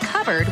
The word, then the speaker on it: cupboard: When we come back, why it's pronounced covered when it's spelled cupboard. What's up with that? – Check it out.